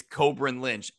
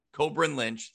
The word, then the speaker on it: Cobrin: It's Cobrin Lynch. I mispronounced it and said Corbin. So we were corrected on Twitter. You nailed that one. It is Cobrin Lynch.